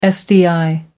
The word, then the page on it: sdi: American English